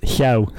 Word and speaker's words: Llew: Yeah, it's Llew. – If I tried to pronounce that correctly, it would sound probably... It would sound so awful that it's not even worth the attempt. – No, don't worry. Lots of Welsh people can't say it right, so you're not alone.